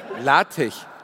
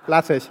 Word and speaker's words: Latex: Latech. – Latech. Schreibt sich aber Latex. – Ach so, wirklich? – Schreibt sich Latex, wird aber Latech ausgesprochen.